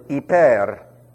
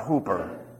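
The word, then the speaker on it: hyper (ὑπέρ): A-P-A-X-Z. How's that? It is translated once. Then we have the preposition hyper. That's E, a long E, P-E-R, not Hooper. If you've heard Hooper, that's fine for somebody who doesn't speak the language.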